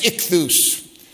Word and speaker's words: ichthus: It's pronounced ichthus.